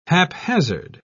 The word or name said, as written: haphazard